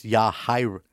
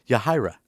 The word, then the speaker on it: Yahira: It's Yahira. Like when you say hi, Ra. Yahira.